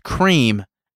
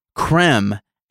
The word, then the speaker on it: Creme: But they initially dropped a tweet saying that it is not pronounced cream as in dream, like we said. We said cream egg, but it is creme as in them.